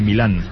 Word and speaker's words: Milán: Y por eso es el Inter de Milán, con acento en la A.